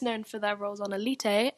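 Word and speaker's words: Élite: I say Elite because, I mean, it's a Spanish show, so surely the title would be in Spanish, so Elite, but it's spelt like the English word Elite.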